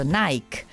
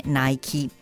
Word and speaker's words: Nike: Come molte altre parole inglesi che finiscono in e, richiedono che la vocale si legga. In Europa tendiamo a pronunciare ad esempio Nike, ma il modo corretto di indicare il famoso brand di sportswear è Nike.